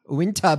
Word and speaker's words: Uinta: Oh, how do you pronounce that? U-I-N-T-A. Uinta? Uinta Basin. – Uinta.